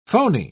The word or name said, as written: phony